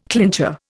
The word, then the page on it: clincher: Indian